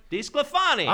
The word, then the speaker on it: DeSclafani: So the next guy I have to bring up, Cincinnati Reds pitcher Anthony DeSclafani. – Yeah. – DeSclafani. Is that right? – DeSclafani.